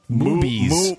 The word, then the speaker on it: Moobies: Woobies or Wooblies. – Woobies. – No, not Woobies. – Woobies. It rhymes with movies. Moobies. – Mooblies. – Moobies. – Moobies. That's it. Moobies.